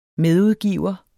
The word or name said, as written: medudgiver